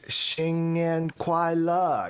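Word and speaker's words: Xing Nian Kuai Le (新年快乐): How would you actually pronounce it? – Okay. Oh, yeah. It's Xing Nian Kuai Le.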